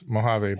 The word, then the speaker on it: Mojave: Sorry, I should pronounce those correctly. It's Mojave, Baja, California, Sonora, and Chihuahua.